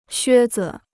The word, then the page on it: 靴子: xuē zi